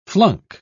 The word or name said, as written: flunk